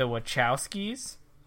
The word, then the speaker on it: Wachowski: Yeah, so this was directed by the, and I will not be able to pronounce this correctly, the Wachowskis.